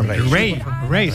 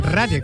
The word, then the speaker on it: Raye: Ray, se escribe Raye. – Raye, con Raye, Raye.